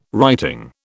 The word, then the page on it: writing: British English